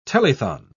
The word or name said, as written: telethon